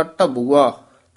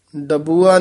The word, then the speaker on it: टबुआ: उगर गया जैसा खोटा टबुआ, डबुआ नहीं कह देना, टड्डा है, टबुआ उच्चारण करना।